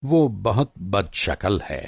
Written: बदशकल

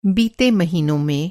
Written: बीतना

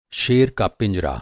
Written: पिंजरा

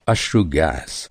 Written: अश्रु

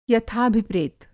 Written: यथाभिप्रेत

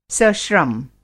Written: सश्रम

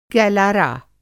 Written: गैलारा

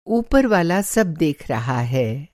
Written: ऊपरवाला

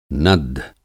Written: नद्ध

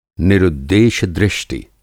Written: निरुद्देश्य-दृष्टि

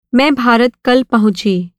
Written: पहुँचना

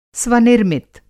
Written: स्वनिर्मित

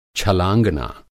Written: छलाँगना

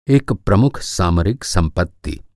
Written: सामरिक

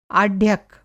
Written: आढ्यक

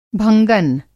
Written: भंगन

